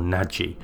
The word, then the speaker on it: Nagy: Nagy is spelt N-A-G-Y. In America, they pronounce it Nagy or Nagy. But every Hungarian I've spoken to pronounces that word as Nagy. And that's what I'll stick to.